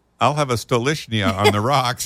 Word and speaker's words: Stolichnaya: No, I had a waitress on a cruise line who was from Russia, and I said, how do you say Stoli? I mean, how do you say that appropriately? She goes, it is Stolichnaya.